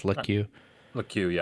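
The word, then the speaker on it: Lecque: The Thunder get Ricky Rubio, Kelly Oubre, Ty Jerome, Jalen I forgot how you say his name. I think it's Lekue, yeah. I heard Woj say it on TV.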